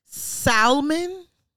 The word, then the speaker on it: salmon: Salmon. Is that what he said? – You pronounce the L in salmon the word me one more time, up.